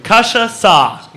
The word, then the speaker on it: cachaça: It is a cachaça, which is a Brazilian type of liqueur.